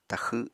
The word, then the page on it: taxw7: apatani